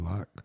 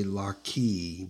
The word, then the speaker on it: John Locke: John Locke. – Locke, yeah. Some people say Lockie because it's spelled L-O-C-K-E.